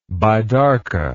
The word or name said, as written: bidarka